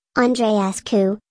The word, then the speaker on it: Andreescu: Bianca Vanessa Andreescu, Romanian pronunciation, Andreescu, born June 16, 2000, is a Canadian professional tennis player.